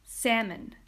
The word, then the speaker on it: salmon: Moving on, we have one of the most mispronounced words in the English language, and that is salmon. Salmon. You know that pink fish that's really expensive, but it's delicious. It just always hits the spot. It's salmon.